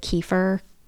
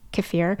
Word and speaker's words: kefir: I know some people pronounce it kefir, I just say kefir.